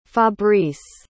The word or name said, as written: fabrice